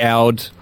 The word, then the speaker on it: oud: You've got all the car screeches and you've got this funky oud. Don't forget how you pronounce it, percussion instrument. – Oud. – Oud, that's it, of course.